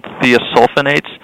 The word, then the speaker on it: thiosulfonates: Garlic has these heart protective compounds that they're called thiosulfonates.